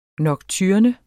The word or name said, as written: nocturne